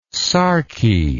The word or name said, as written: sarky